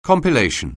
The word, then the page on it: compilation: anglais